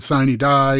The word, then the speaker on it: sine die: and we pronounce it sine die, and no confetti this year.